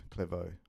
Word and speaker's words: Clairvaux: It's French, Clairvaux. – Oh, it's spelled very like Clairvaux. – Yeah, but you pronounce the A-U-X as Vaux. Clairvaux.